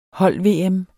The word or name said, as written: hold-VM